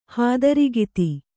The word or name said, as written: ಹಾದರಗಿತ್ತಿ